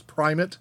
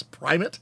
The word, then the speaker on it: primate: I do know it's primate, even though it's spelled primate. It's primate.